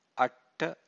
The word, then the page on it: atta: Pali